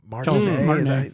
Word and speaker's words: Martinet: And so there's this guy that can imitate Mario really well, or Charles Martinet.